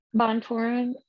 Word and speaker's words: Bontorin: I think Bontorin, is that how you pronounce it? – Bontorin is maybe a little bit better striker, but I think Royval is a better grappler, and he's a more active grappler.